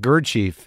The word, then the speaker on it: Gurdjieff: Yeah, you sort of riff a little bit about Gurdjieff. Is that how you pronounce his name? – Yeah, that's it.